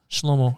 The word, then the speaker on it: Shlomo: So does that help you get all kinds of info on Shlomo that you didn't know? For anyone wondering, that's how you pronounce it in Hebrew. Shlomo.